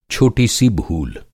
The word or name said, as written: छोटा